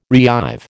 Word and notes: reive: American English

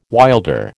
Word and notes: wilder: American English

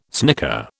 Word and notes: snicker: British English

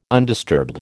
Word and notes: undisturbed: American English

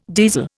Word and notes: diesel: Indian

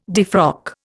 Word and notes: defrock: Indian

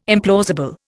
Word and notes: implausible: Indian

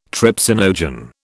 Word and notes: trypsinogen: British English

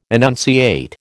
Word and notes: enunciate: American English

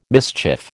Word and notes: mischief: American English

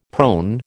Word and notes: prone: American English

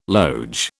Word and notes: loge: British English

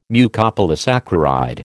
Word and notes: Mucopolysaccharide: American English

